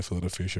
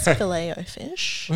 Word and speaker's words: Filet-O-Fish: Look, it's not the only thing I order, but I've partaken in a Filet-O-Fish every once in a while. – My God. – First of all, you're pronouncing it incorrectly. It's Filet-O-Fish.